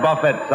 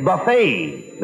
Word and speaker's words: buffet: I invited him over to my house for a buffet supper. – Not buffet supper. It's buffet. The tea is silent.